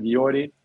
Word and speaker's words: Jordi: Well, we usually say, well, if you get to England, they say like Jordi, but it's, yeah, we just pronounced it Jordi.